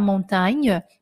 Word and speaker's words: montagne: Il n'y a ni la mer, ni la montagne. Não tem nem mar nem montanha. Presta bem atenção no um, no, em como você pronuncia montanha. Este A do tá é forte, ele é montagne montagne, d'accord Montagne.